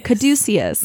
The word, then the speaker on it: Caduceus: Okay, so how do you say it? – So here's Google. – Caduceus. Caduceus. – All right, Caduceus.